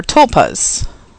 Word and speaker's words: tulpas: And one of the theory that Nick gives in the book as a possible explanation for Men in Black is that they are tulpas. Did I say that right, Rick? – Yes.